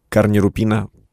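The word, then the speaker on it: корнерупін: Саме тому наступний лот, який я обрав, крупний крістал, карнірупіна. Правильно наголос, так? – Да.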